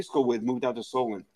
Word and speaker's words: Solon: JB, Jared from Solon. That's Solon. – Solon rhymes with stolen.